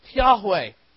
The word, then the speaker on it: Yahweh: God revealed his covenant name to Moses, I am, or in the Hebrew we pronounce it Yahweh.